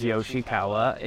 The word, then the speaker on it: Yoshikawa: So my name is Ka'eo, and my last name is Yoshikawa.